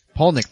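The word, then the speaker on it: Palahniuk: Paulnick.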